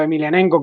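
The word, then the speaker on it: Emelianenko: Pero tanto Emilianenko como, como que es con K, aunque se pronuncia con la J, eh, son no de la época de MMA de los dos, ¿no?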